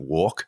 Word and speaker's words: WARC: There's research that WARC, it's pronounced like that, it's spelled WARC.